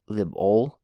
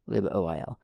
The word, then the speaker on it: libOil: Okay, libOil, O-I-L, oil. Say that as 2 syllables, libOil, libOil.